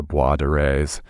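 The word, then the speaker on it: Boisdoré: the Bois de Rays. And of course, I hope I'm saying that correctly. – You are doing very well.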